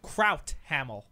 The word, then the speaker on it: Crouthamel: Crouthamel. Spell that. – C-R-O-U-T-H-A-M-E-L. – Crouthamel. – Yes. – Like sauerkraut.